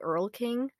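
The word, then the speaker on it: Erlking: his name is the Earl King, which is how we're pronouncing it, because we do not have German accents.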